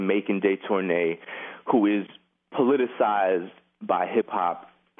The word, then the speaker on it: Detornay: Am I pronouncing all that? – I say Detornay.